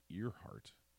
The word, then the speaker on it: Earhart: Earhart. That's how you actually say it. That's how it's spelled. But it's pronounced Earhart.